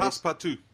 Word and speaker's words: Passepartout: Passepartout.